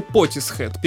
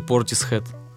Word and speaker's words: Portishead: Я все время говорю Portishead. – Ну, не знаю, вот, мне кажется... Мне, понимаешь, они англичане. Мне кажется, по английским меркам э, там все зависит от того, где ты находишься.